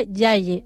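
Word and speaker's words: yaay: También tenemos la palabra madre en Wolof, que se pronuncia Yaye.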